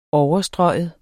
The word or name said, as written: overstryge